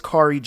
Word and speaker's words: Khari: Kari is spelled K-H-A-R-I. And then, you know, the letter G. It's Kari G.